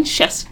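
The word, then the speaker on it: Scieszka: But John Sheshka. – Sheshka. – Rhymes with Fresca.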